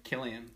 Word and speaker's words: Cillian: Is it Cillian again? – Killian. – Killian Murphy.